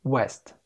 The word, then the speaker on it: west: W-E-S-T. إيش معنى؟ لأن دي نطقها كده west. West. سريعة كده. إنما الاتنين التانيين نفس النطق. نطقهم كده west. West. بطيء شوية. يبقى عندي أول كلمتين نفس النطق بطيء waste، والكلمة التالتة نطقها مختلف عنهم وهي سريعة شوية west west.